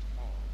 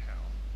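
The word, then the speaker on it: Hal: Ja, das ist von Herr Lindsay, übrigens, wenn Sie den Namen aussprechen wollen, nicht Hall, sondern Herr. Das ist die Aussprache im Englischen. H-A-L-L ist Hall.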